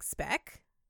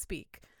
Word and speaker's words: Speke: It's spelled S-P-E-K-E. – Yes. – And I was like, Speck?